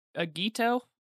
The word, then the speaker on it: Agito: I'm gonna say it wrong again, Agito sounds... – Yeah, no, that's correct.